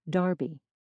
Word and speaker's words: derby: Although this sound has mostly died out in the United States, it's still heard in England in words like clerk and derby, which are pronounced something like clark and darby. Other sounds were slightly different too. For instance, words that end in U-R-E were pronounced without the gliding Y sound, so creature, pasture, and nature were said creator, pastor, nater.